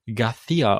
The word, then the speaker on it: García: Oder äh, und äh, das ist auch ein relativ üblicher spanischer Name und der wird García ausgesprochen. Also äh, ein C, das schon äh, C geschrieben und das wird aber wie so ein TH ausgesprochen, aber nicht nicht wie im Englischen, sondern halt eben ein bisschen äh, bisschen mehr Spucke im Mund.